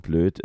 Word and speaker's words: Bloeth: Deswegen dann Herbert Anton Hilger, weil Blöd ist, blöd. – aber nicht so geschrieben wie Blöd, sondern B-L-O-E-T-H. Aber man spricht halt Blöd.